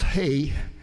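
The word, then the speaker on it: hay (ה): It's pronounced hay, basically.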